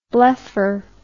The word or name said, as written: blephar